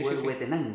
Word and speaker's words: Huehuetenango: Bueno, uno de los ministriles que están documentados en América es precisamente Santa Eulalia de Huehuetenango en Guatemala. – Sí. – ¿Lo dije bien? – Sí, Huehuetenango. – Sí, sí.